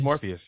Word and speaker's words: Morpheus: would this be wild that if I told you that Neo and Trinity and Morphe was I about to say his name right? Morpheus? – Morpheus, yeah, Yeah, okay.